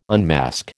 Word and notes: unmask: American English

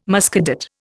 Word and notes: Muscadet: Indian

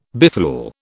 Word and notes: befool: British English

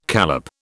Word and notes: callop: British English

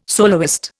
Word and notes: soloist: Indian

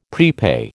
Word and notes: prepay: American English

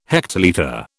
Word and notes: hectolitre: British English

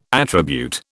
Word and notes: attribute: British English

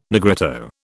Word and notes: Negrito: British English

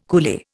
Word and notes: coolie: Indian

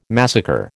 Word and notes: massacre: American English

